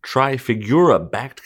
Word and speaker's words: Trafigura: Moving right along, Russian Lukoil to sell strategic Italian refinery to the Trafigura-backed company, if I pronounce that correctly. – You did.